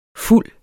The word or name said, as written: fulde